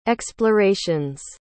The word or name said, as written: explorations